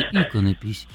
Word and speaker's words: иконопись: Абсолютно все словари фиксируют ударение в данном слове только на первом слоге, Ириночка, представляете? Поэтому правильно говорить иконопись.